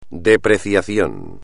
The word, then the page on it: depreciación: espagnol